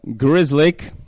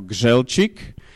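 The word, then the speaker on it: Grzelczyk: No, takže, ako hovoria Grizzly v Amerike, keď si napíšeme to meno, keď si ho teda pozrieme, vôbec tie písmenka nejdu v takom poradí, ako ho vyslovíme, teda Grizzly. Je to poľské priezvisko, teda pochádza z Poľska, samozrejme naturalizované v Amerike a skúste to dať vysloviť Američanovi, vôbec to nevysloví, tak si to upravili, normálne by ste to vyslovili po poľsky ako Gželčik.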